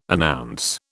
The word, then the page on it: announce: British English